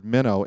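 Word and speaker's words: meno (μένω): It's the Greek word meno, M-E-N-O, long O.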